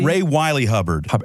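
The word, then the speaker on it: Ray Wylie Hubbard: Ray Wiley Hubbard.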